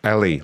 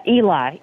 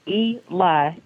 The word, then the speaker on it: Elie: Eli, E-L-I-E. – Okay. Like Ellie, like the girl's name. – Eli. – Ellie, okay. Let's go ahead and patch him in. – Pronounce it Eli, like E and like if you're telling a lie, Eli.